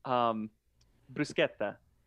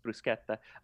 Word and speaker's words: bruschetta: When people say bruschetta. Everybody says bruschetta. – Yeah. – but yeah, no, because that's how it would be pronounced in English, or in, you know, German or anything that SCH, but in Italian it's bruschetta.